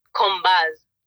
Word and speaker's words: Combaz: C-O-M-B-A-Z. – Combaz. – See, you pronounced the Z.